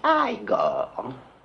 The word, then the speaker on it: Igor: Non, ça se prononce Igor.